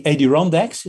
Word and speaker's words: Adirondacks: And first he went to the Adirondacks.